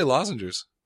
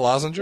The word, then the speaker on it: lozenge: How do you say lozenges? – Lozenge. – It's not a lozenger? – It's a lozenge.